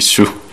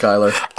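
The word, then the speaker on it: Schuyler: Relationships, Will Graham, Hannibal Lecter, Kurt Blaine, Lin-Manuel Miranda, Jonathan Groff, Ted Cruz, Marco Rubio, Jeb Bush, Peggy Shu. What is that? – Skylar. – It's pronounced Skylar.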